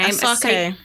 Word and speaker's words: Asake: Asake.